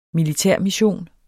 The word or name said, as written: militærmission